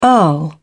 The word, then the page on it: earl: British English